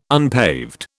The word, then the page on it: unpaved: British English